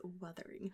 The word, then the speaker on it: Wuthering: It's Wuthering Heights. – How do you say it? – Wuthering Heights. There's a U in there, not an I. Oh. No one's withering.